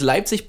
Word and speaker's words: Leipzig: Leipzig, right? Did I say it right? – Uh, you can actually it's Leipzig, but oh, uh, oh shit, Leip- Leipzig.